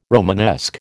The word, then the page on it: Romanesque: American English